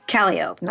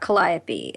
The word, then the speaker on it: Calliope: It's Calliope, not Calliope. It's Burgundy, it's not Burgundy.